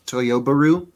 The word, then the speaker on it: Toyobaru: By the way, the correct pronunciation, because everyone's trying to combine Subaru and Toyota, it's Toyobaru. Because we've done this with BRZ and GT86 before.